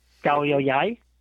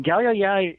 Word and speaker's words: Koh Yao Yai: K-O-H-Y-A-O-Y-A-I, Galyayai. – Right. – And Galyayai is actually, there's two islands of that chain.